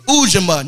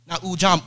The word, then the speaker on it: Ujima: And Ujima is the proper pronunciation. That's how Dr. Milana Karenga says it. So we're going to say Ujima. Not Ujam.